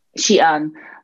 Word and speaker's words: Xi'an: Xi'an.